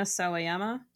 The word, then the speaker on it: Sawayama: I liked that about your playlist, like Take Me As I Am by Rina Sawayama.